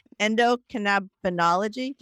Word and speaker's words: endocannabinology: So can you just talk about what you're doing with your fellow physicians, I guess your whole family, and endocannabinology? Did I say that correctly? – Can you just, yeah, you nailed it.